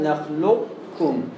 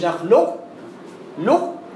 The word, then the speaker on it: نخلقكم: نخلقكم بالكامل لا لنا الناقص كمان الناقص يعني ايه بقى الناقص ان انا اخش بصفة الاستعلاء على القاف وبعدين اطلع بالكامل ألم نخلقكم نخلقكم مش نخلق لق يعني انت ما تروحش تروح ناحية القاف كأنك هتنطق القاف بس ما تنطقهاش وتروح